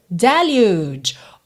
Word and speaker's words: deluge: Atenção que o som da letra U aqui é como o nome da própria letra U, que é U. E esse G acaba soando um pouco como se fosse um DJ. Deluge, deluge, ok?